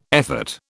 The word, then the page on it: effort: British English